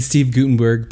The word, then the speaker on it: Steve Guttenberg: And Steve Guttenberg played, that's how you say it, right? – Yeah.